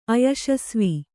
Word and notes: ಅಯಶಸ್ವಿ: ayaśasvi

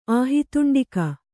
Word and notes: ಆಹಿತುಂಡಿಕ: āhituṇḍika

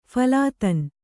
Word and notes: ಫಲಾದನ: kannada

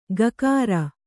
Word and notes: ಗಕಾರ: gakāra

